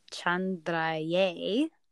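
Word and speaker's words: Chandraya: And Chandra, when you're chanting it, is Chandraye. Or if you can't roll your R's, Chandraye.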